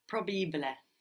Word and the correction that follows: probabile: Think of how it's written. How it's spelled P-R-O, no? B-A. Proba. – Probabile. Probabile. It's almost like there's an extra B.